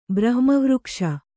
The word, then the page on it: ಬ್ರಹ್ಮವೃಕ್ಷ: brahma vřkṣe